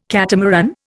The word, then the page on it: catamaran: Indian